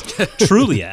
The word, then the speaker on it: Trulia: because they're integrating that Trulia, see, I pronounced it correctly, Chris, Trulia acquisition.